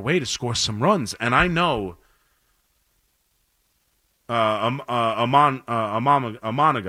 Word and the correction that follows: Imanaga: I always say that word wrong, who's now, I always say his name wrong. Say it to Imanaga, thank you.